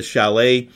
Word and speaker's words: Shallot: And it's, I believe they pronounced it the Chalet. It looks like it's spelled Shallot, S-H-A-L-L-O-T, and they don't make it anymore, which sucks.